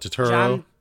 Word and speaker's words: Turturro: John Turturro.